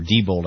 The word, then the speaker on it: Diebold: But, frankly, I have a hard time, Kodak, believing that a corporation like Diebold, or Diebold, I think is how it's actually pronounced, would engage in vote fraud.